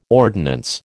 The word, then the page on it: ordinance: American English